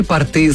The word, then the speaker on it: порты: Основными критериями в запросе указаны широкие порты с карманами. Поскольку аналогичные запросы уже поступали от собрания акционеров первой портовой компании, принято решение официально закрепить ударение на первый слог в слове «порты».